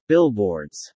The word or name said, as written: billboards